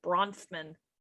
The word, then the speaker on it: Bronfman: So this was paid by Barbara Brofman, a Montreal socialite who married into the wealthy Bronfman. How do you say that? – Bronfman. No, you're adding like extra syllables. I think it's just Bronfman.